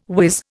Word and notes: wiz: Indian

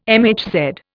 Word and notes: MHz: Indian

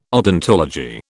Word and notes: odontology: British English

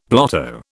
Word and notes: blotto: British English

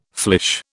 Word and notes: flèche: British English

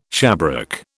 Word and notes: shabrack: British English

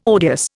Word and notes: odious: Indian

